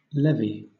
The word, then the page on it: levy: British English